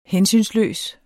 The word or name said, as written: hensynsløs